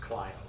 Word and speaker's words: Clio: It's actually pronounced Clio.